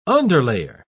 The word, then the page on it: underlayer: British English